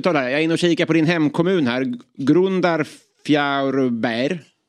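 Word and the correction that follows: Grundarfjörður: Var det okej? Okay? Eller säger du? Grund, eh, Grundarfjärdur.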